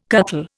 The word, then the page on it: cuttle: Indian